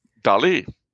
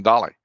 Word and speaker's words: Dali: And he was very impressed with her because she pronounced his name properly. – Okay. – Dali. – Yeah. – That's where the accent is on the I. – Yeah. – But everybody calls him Dali.